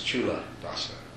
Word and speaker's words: Culadasa: But C in Pali is pronounced like CH in English. So it's Chula Dasa.